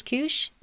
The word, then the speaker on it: Kush: So Kush is short for the winemaker's name is Kush Geryon. That's his last name.